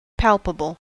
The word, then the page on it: greifbar: /ˈɡʁaɪ̯fˌbaːʁ/; [ˈɡʁaɪ̯fˌbaːɐ̯]